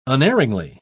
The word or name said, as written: unerringly